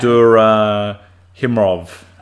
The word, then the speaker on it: Abdurakhimov: I'm not going to attempt to pronounce his last Abdurakhimov. – Abdurakhimov.